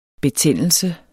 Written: betændelse

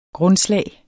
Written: grundslag

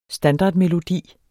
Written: standardmelodi

